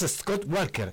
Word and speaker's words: Scott Walker: Ahí está entonces, amigos, Scott Walker. ¿Está bien pronunciado? – Sí.